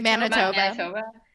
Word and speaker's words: Manitoba: 19 in all provinces except Alberta, Manitoba. How do you say that? – Manitoba.